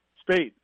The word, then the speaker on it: Speight: Spite. Uh, I'm not sure how to pronounce his last name, Spate, or Spite. – Spate. Um, Wilton Speight, but he's a, he's a tough kid.